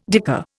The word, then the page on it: dicker: Indian